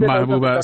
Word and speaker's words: محبوبه: بسیار زیاد تشکر از شما خانم معجوبه سراج، از فعالان برجسته زن. – معجوبه نیست، نام من محبوبه است. – محبوبه سراج، بله، معذرت میخوایم.